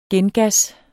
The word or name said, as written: gengas